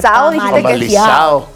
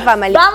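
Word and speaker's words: Famalicão: El famalizado. – Famalizado. – Sí. Porque no se dice famalicao.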